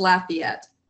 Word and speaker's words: Lafayette: It's pronounced Lafayette. So you kind of emphasize the la part of it, but it's a very Acadian.